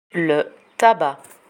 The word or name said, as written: le tabac